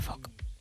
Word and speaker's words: folk: Su regla clave es que la letra L es muda. – Anda. – Con lo cual se pronuncia folk.